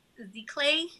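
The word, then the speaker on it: giclée: So I think it's French. And as far as I have researched, it's Gicle. Mm-hmm. It would be the pronunciation.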